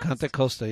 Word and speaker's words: Contra Costa: Contra Costa, yeah.